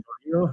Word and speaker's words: Chourio: Jackson Choro, is that correct? – Jackson Chorillo is what I would say.